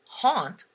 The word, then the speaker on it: haint: Of southern origin, the blue known as haint, pronounced haunt up here in the north, and spelled H-A-I-N-T, haint, is said to have the power to ward off the influence of restless spirits, especially the evil kind.